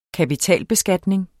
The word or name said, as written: kapitalbeskatning